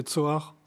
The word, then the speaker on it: Johari: à la basse, on a Johari, mais écrivez-le Johari, mais prononcé Tsoar,